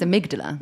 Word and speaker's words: amygdala: How do you pronounce it? – I think it's amygdala. – Yeah, you're right.